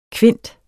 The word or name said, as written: kvint